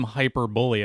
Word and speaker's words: hyperbole: but I'm hyperbole about hyperbole. – You are hyperbole. – Hyperbole. – It took me a long time to connect the seeing the written word and the pronunciation of hyperbole.